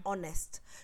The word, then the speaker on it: honest: like honest. – So because H is not pronounced in honest,